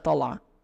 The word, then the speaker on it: tala: Tala is used very subtly, and in Palestinian Aramiya, it's often the ayin is often very subtle. It will be pronounced like tala, tala. It's very, very subtle. So here at the beginning, where he says tala, shusmakum marra, it almost sounds like tab, tala.